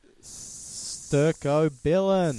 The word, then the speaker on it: stercobilin: Into stercobilin.